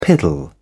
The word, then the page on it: piddle: British English